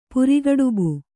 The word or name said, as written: ಪುರಿಗಡುಬು